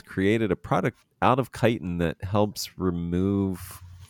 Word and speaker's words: chitin: How do you pronounce? It's chitin, right? – Chitin.